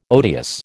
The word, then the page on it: odious: American English